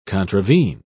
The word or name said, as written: contravene